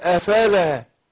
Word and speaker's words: أفلا: عايزين افلا دي نعدلها عشان كتير بنطوق افلا بانطقاق كانها لام مفتوحه، دي هنا لام الف، اقول افلا افلا تتقون افلا تعقلون افلا تبصرون افلا، مش افلا، افلا من الافول،